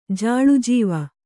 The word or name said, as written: ಜಾಳುಜೀವ